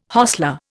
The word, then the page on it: hostler: Indian